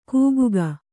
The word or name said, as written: ಕೂಗುಗ